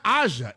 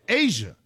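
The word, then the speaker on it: A'ja: Aja Wilson is a face of the league. I apologize. – You're not even saying it right. You're saying it's not Aja. It's Asia.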